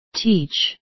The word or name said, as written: teach